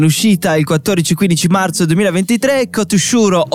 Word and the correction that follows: Katsuhiro: Otomo. No, alla regia, ho detto giusto? – Katsuhiro Tomo. Katsuhiro, ecco, Toshuro, eh, siamo amici d'infanzia, no?